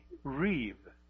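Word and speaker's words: reeve (ריב): And that term is reeve. The B, and it's in your handout, the B is pronounced like a V, reeve.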